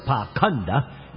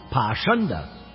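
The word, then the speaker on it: Pāṣaṇḍa: The word Pakhanda used in this section is sometimes pronounced Pashanda.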